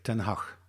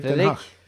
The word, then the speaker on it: Ten Hag: Erik Ten Hag.